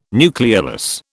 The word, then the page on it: nucleolus: British English